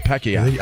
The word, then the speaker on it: Pacquiao: Right, I say it the way it's supposed to be said. – All right. – Pacquiao. – I think right. – That's how they say it. Pacquiao.